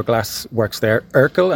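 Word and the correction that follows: Rückl: am I saying that right? – Rickel, yes.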